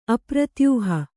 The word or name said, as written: ಅಪ್ರತ್ಯೂಹ